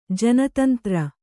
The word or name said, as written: ಜನತಂತ್ರ